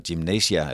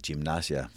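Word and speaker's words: Gimnasia: han stadigvæk var træner for Gymnasia, eller Gymnasia, som, hvor de nu udtalte.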